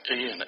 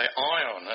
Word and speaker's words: Ian: People who live on the west coast of the USA have terrible problems saying Ian, even though it's spelled I-A-N. And they always say Ion.